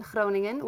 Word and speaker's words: Groningen: So right now I'm in Groningen, which is the Dutch pronunciation for Groningen in the Netherlands, which is like 3 hours away from Amsterdam.